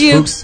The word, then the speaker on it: Fuchs: I believe it's pronounced Fuchs. – Fuchs, maybe. Fuchs. Fuchs. – It's the German spelling of Fox.